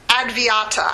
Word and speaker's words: Advaita: Advaita, I think is how it's pronounced. It's A-D-V-A-I-T-A. A-D-V-A-I-T-A.